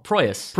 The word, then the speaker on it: Proyas: Alex Pryoros. How do you say that guy's name? – Who? – The guy who did Dark City. – Oh, Proyas. – Proyas, thank you.